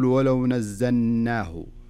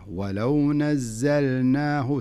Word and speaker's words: ولو نزلناه: في قوله تعالى ولو نزلناه، زين لاحظ اللام، مش عليها اللام، عليها سكون وسكون ظاهر بين معنى، معناها أنك لابد تنطق اللام. فلذلك لا تقول ولو نزلناه ولو نزلناه، لا خلها اللام ظاهرة ولو نزلناه، تنطق اللام ثم تنطق النون.